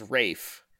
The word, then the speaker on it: Ralph: But to pronounce the name Ralph as Rafe, what's going on here? – Well, his dad is called also Ralph, but he also goes by Ranulph. – Okay, but is this a British pronunciation of the name Ralph that I don't know? – Yes, it is.